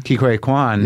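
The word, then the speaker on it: Ke Huy Quan: Ki Hui Kwan.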